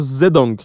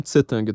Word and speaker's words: Zedong: Aliás, eu não sei, fazendo aqui uma, uma segunda nota aqui para quem fala chinês, eu sei que parece que o nome dele é pronunciado Zedong, não Tsetang.